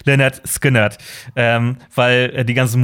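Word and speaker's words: Lynyrd Skynyrd: Kein Schwein wusste, wie sie ausgesprochen werden. Ähm, dann haben sie aber das Album äh, so genannt, wie sie ausgesprochen werden, nämlich äh, Le-Nerd Skin-Nerd.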